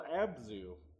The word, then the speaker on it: Abzu: and it's called Abzu. I guess that's how it's pronounced, A-B-Z-U.